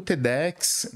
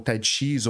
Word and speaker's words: TEDx: E aí o TEDx, né? O TEDx ou TEDx, todas as pronúncias estão corretas.